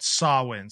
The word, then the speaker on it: Samhain: It's spelled Samhain, but it's like, if you look at the pronunciation, it's called Samhain.